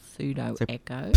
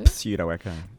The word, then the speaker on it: Pseudo Echo: No one knew really how to spell Pseudo Echo. – Pseudo Echo. – Pseudo. – Pseudo Echo. See, it's very Echo. Clever because the E is silent in that.